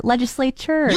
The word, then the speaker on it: legislature: I don't feel like it has a CH. – It does. – It's chur. Legislature.